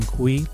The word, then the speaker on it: Cui: And of course, you could find me, Brian Kui, last name is spelled C-U-I, on LinkedIn.